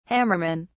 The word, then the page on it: hammerman: British English